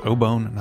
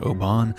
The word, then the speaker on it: obon: It turns out we pronounced the Japanese word obon incorrectly. The entire episode is pronounced obon, not obon.